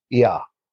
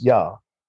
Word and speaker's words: Yah: So let's consider that Enki was known in the Babylonian as Yah, which is pronounced the same as Yah, as in Yahweh. It also resembles the Canaanite Yahu.